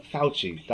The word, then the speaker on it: Fauci: Fauci.